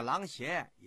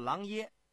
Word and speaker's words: 琅邪: 哎，就在这阵恰好啊，琅邪郡的赣榆有一个叫徐福的方士，就是古代啊求仙啊炼丹的人，跑到狼爷这儿给这秦始皇上书，说他有办法啊，寻觅到这个仙药。 – 听众朋友们，这个狼邪也叫狼爷，这个邪啊是个多音字，喜耶邪或者就念耶。